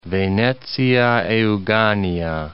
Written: Venezia Euganea

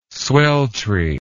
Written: sweltry

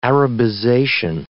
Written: Arabization

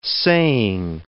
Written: saying

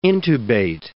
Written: intubate

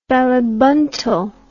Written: ballibuntal

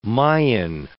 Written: Mayan

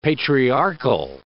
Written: patriarchal

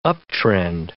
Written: uptrend

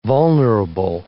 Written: vulnerable

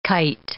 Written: kite

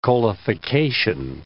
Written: coalification

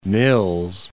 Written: Mills

